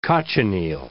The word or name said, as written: cochineal